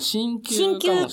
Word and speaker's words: 鍼灸: 突然ですが、お二人は、ハリキューの治療を受けられたことありますか？ – はいはい、ハリキューはどうかな？ – これ、ハリって読んで、いいんだよね。 – あ、でも、鍼灸かもしれない。鍼灸かもしれないね。ごめんなさいね。